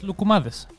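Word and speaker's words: lugumadis (λουκουμάδες): Say it like that? Just lugumadis.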